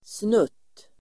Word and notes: snutt: [snut:]

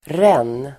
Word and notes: ränn: [ren:]

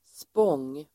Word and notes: spång: [spång:]

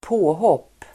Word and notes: påhopp: ²p'å:håp:

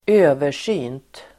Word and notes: översynt: [²'ö:ver_sy:nt]

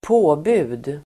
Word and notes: påbud: ²p'å:bu:d

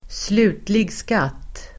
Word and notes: slutlig skatt: [²sl'u:tlig sk'at:]

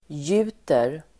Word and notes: gjuter: [j'u:ter]

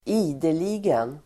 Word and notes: ideligen: [²'i:deli:gen]

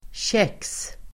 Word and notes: käx: [kek:s el. tjek:s]

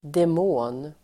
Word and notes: demon: dem'å:n

